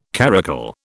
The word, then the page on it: caracul: British English